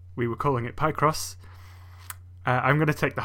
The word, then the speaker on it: Picross: And second of all, I can read, I understand grammar, and that is pronounced Picross. – I guess it makes sense, though, because it's a combo of pick and cross.